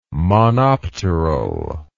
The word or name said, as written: monopteral